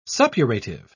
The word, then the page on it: suppurative: British English